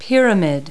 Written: pyramid

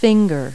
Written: finger

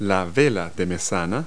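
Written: mizzen sail

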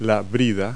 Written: bridle

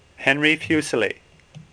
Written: Fuseli

